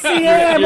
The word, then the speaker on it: Siam: Baby face CM over here. – The George Lazenby. – I am saying that right, though, right? It's CM. – CM. – Yeah. Spelled Siam, but CM.